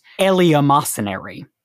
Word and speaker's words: eleemosynary: At some point, the English word was pronounced eleemosynary, but it's just so hard to pronounce it that way that it slowly went out of context. And there was a separate mark on the second E to show that it was pronounceable, that you should pronounce it separately.